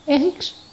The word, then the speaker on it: Henriques: É Sean Paul Ryan Francis Henriques. Que chique. – Henrique. – Henriques, exatamente como se escreve em português.